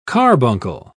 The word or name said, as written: carbuncle